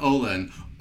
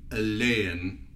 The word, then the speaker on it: Ohlen: I have in studio with me today Jessica Ohlen, or as is pronounced in Sweden, Ohlen, but here in Utah it's Ohlen.